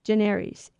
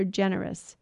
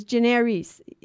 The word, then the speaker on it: generis: But it's generis, because the I in Latin, by the way, is long E. So it's not an I. It doesn't sound like I. It sounds like E. So generis.